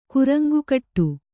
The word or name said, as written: ಕುರಂಗುಕಟ್ಟು